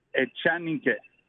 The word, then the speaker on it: Echanique: Did I say it right? – Echanique.